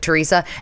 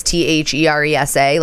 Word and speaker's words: Theresa: And I was like, Teresa.